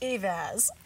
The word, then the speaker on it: Eivaz: It's Jennifer Eivaz. – Eivaz. – Eivaz. Yeah. See, I said it wrong again.